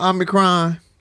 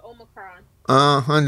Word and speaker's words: Omicron: So listen, since we're talking about cooking and cracking and kitchens and stuff, can we talk about Omicron? Am I saying it right? – You're not. It's called Omicron.